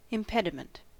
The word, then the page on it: impediment: American English